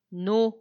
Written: nu

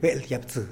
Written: beeldyapsï

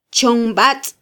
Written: chonbatz

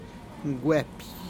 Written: gueppih

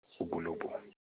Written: Ugolobu

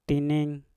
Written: tinin

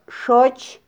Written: xoch

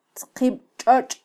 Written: tzqibtxotx

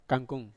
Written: kangkung

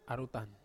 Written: arutan

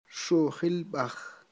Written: xujilb'aj

